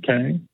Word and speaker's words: Caen: C'est c'est au 976 rue de Caen. Je ne sais pas comment ça se prononce. C'est A-E-N. – Oui. – Caen. – Oui. – Oui, c'est ça.